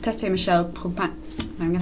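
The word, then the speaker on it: Tété-Michel Kpomassie: It's Tete Michelle Pomasi. I think that's as close as I'm going to get.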